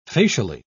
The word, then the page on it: facially: British English